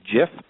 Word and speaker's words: GIF: Well, it has been settled because the gentleman who created the graphics interchange format, or GIF, has settled that and said that it is, in fact, to be pronounced GIF. Here in the United States, there is a peanut butter known as GIF. So if you think of that, it is officially a GIF and not a GIF file.